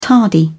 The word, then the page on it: tardy: British English